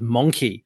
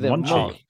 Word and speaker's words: Monchi: Is that how you say it? – I think it's Monchi.